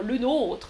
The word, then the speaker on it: nôtre: La bravo, ce n'est pas votre travail, c'est le nôtre, le nôtre. Attention à la différence de prononciation. Notre, le nôtre. Notre, oui c'est ça, c'est le nôtre.